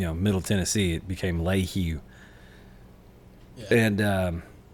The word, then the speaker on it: Lehew: It's I think my favorite, because I mean, it's for those of you who don't know, it's spelled L-E-H-E-W and it's pronounced Lehu.